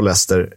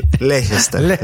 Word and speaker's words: Leicester: Och här har du ett jäkligt bra uttal på, på Leicester. Eh, Leicester.